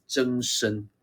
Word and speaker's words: 曾参: 我相信我们都有听过说曾子，曾子啊，来，曾子的本名哦叫做曾参，这个参呢是参加这个参哦，只是念作参哦，曾参。